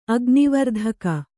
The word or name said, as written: ಅಗ್ನಿವರ್ಧಕ